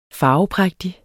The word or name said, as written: farveprægtig